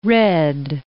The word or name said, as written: redd